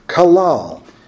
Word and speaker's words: qalal: Now, this word curse is from a Hebrew word, kalal. We would transliterate that as Q-A-L-A-L. Kalal.